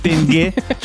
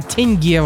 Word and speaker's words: тенге: Поцелуй в тенге? Что, о чем речь? Не понимаю. – Не тенге, а теньге вообще-то.